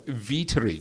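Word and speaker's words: Weitering: I might say that on the footy show on Thursday night, David Hughes did ask him how his surname is pronounced. – Yes. – To which he replied, Vietering.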